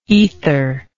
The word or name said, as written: aetheric